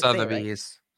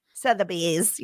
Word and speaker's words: Sotheby's: Is that how you say it? I can't Sotheby's. – Sotheby's. Yes, I knew you'd know.